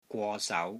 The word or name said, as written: GWO3 SAU2